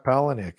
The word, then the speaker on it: Palahniuk: Chuck Palahniuk. Yeah. – And I fucking know. No, you said it right.